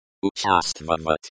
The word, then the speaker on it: участвовать: Pronunciation: Участвовать. Участвовать. The O sounds like the O in hot. The stress is on the second syllable.